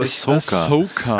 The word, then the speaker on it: Ahsoka: Also Ahsoka.